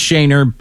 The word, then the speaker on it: Schoenherr: It is pronounced Shaner, but it's spelled S C H O E N H E R R.